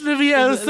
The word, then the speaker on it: Leviosa: It's pronounced It's Leviosa, not Leviosa.